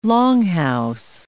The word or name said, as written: longhouse